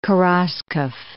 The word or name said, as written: Kheraskov